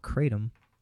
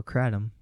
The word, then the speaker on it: kratom: So my mom uses CBD oil, and my dad uses CBD oil, and my mom also uses a plant called kratom, or kratom, depends on how you want to pronounce it, which is of the coffee plant, which acts as if it's an opium, but however, it is not an opium.